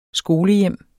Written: skolehjem

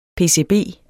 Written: pcb

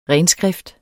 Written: renskrift

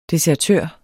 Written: desertør

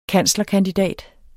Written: kanslerkandidat

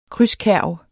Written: krydskærv

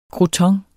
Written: crouton

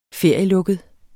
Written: ferielukket

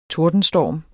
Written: tordenstorm

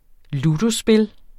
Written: ludospil

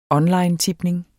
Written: onlinetipning

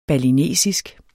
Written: balinesisk

